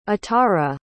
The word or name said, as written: atarah